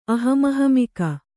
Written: ಅಹಮಹಮಿಕೆ

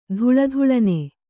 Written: ಥಳಥಳನೆ

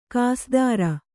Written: ಕಸದಾರ

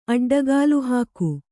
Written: ಅಡ್ಡಗಾಲುಹಾಕು

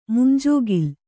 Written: ಮುಂಜೋಗಿಲ್

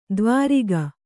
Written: ದ್ವಾರಿಗ

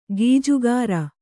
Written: ಖಜ್ಜಾಖೋರ

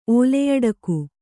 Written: ಓಲೆಯಡಕು

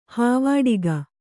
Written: ಹೂವಡಿಗ

